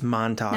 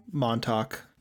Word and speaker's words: Montauk: Is that how you pronounce it? I'm actually not sure. – It's Montauk. – Now that I'm saying it out loud. – Montauk. Yeah. Montauk.